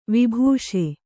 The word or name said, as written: ವಿಭಾಸಿ